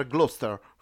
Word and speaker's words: Gloucester: Near Gloucester.